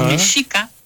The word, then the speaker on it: mexica: Como le sonaba, entonces, así fueron distorsionando palabras, pero la palabra mexico ajá. y mexica era con sh. Entonces, todos los cronistas de aquella época pronunciaron siempre mexico y mexica.